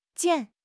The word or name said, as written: 溅